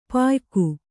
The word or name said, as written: ಪಯ್ಕ